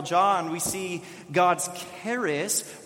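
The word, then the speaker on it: charis (χάρις): This idea of grace that we're talking about today, in the Greek, it's pronounced charis, and this word charis is used to describe an undeserved gift received or given by someone else.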